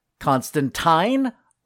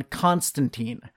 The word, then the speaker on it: Constantine: As you just heard, in movies and TV, he is John Constantine, correcting those who refer to him as Constantine. While in the comics, we have the reverse, specifically telling people to pronounce it Constantine.